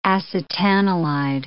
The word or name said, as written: acetanilide